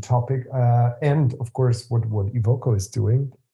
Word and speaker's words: Evoco: But before jumping deeper into the activities of your company, which is called Evoco, I hope I said it right.